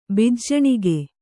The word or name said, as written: ಬಿಜ್ಜಣಿಗ